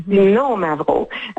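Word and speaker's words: Xinomavro: On dit qu'il y a un accent sur le « no », donc mm-hmm. « Sino Mavro ». Donc, il y a vraiment une intonation importante.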